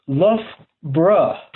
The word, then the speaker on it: Loughborough: I will talk about childhood, but I'm sure your most burning question just now is where is Loughborough, which is actually pronounced Loughborough.